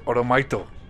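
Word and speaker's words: Oromaito (オールマイト): Como lo pronuncian es Oromaito.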